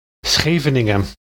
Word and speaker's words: Scheveningen: The Dutch would use the name of a seaside town located near The Hague called Scheveningen. – Germans, and pretty much everyone else on the earth for that matter, cannot pronounce the word properly. The glottal sounds the Dutch use don't exist in German.